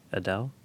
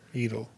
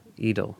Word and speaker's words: Edel: It was directed by a guy named Uli Edel, Adele? – I pronounce it like Eden, but with an L. Edel. – Edel.